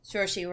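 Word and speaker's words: Saoirse: Sorcy. Yeah. – Sorcy Ronan. – I thought. – It's, I thought so. Uh, I thought she was. – rhymes with inertia.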